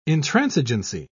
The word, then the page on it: intransigency: British English